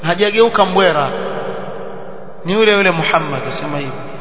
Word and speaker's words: Muhammad: Ndio yule mmoja wao akasema Muhammad nikimwita Mamadi ndio ashakuwa Mburamadi. Akasema hivyo, Muhammad nikimwita Mamadi ndio ashakuwa Mburamadi. Ah, Muhammad ni Muhammad vile vile, usiuite Mamadi, utakuwa mbadilisha lile jina, halikutamkwa sawa sawa, sio Muhammad Muhammad.